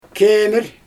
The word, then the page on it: kemIr: Xyzyl